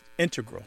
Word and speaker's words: integral: Yeah, but both correct, just to clarify here, both correct, but the mathematicians and math-using scientists are more likely to say integral, with the stress on the first syllable.